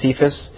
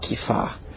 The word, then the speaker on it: Kepha: I know everyone pronounces Peter's name here as Cephas, but I honestly think that Mel Gibson got it right when he pronounced it Kepha, is how it's pronounced.